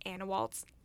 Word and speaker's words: Anewalt's: business called Anawalt's Landscape Contracting.